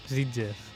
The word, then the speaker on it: Sitges: Me encanta cómo dices Sitges. – Sitges. – Lo dices súper bien. – Sí. – Sitges con Sitges. CH. Pero Sitges. No, tú dices Sitges. Tú pronuncias Sitges. La T.